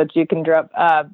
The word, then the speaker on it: Jeukendrup: Asker Jukendrup, if you can spell that, A-S-K-E-R, and then his last name is J-E-U-K-E-N-D-R-U-P, so Jukendrup.